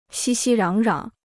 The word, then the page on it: 熙熙攘攘: xī xī rǎng rǎng